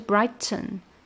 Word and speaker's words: brighten: 然后呢，下半句的难点呢，就是跟上页说的那个 important 一样的处理，就是把那个 t 给吞掉了。这个是美式发音非常常见，但是有一点点偏中高阶的一个发音。就是把这个 t 给吞掉，嗯，然后取而代之的呢是一个停顿加一个嗯，brighten，它本来是 brighten，但是呢，它把这个 t 给弱化，变成一个鼻音，就是 brighten，brighten，然后 enlighten 也是的，变成了 enlighten，enlighten you，enlighten you，呃，然后这个地方就涉及到了非常果人的 r、 l 和 n。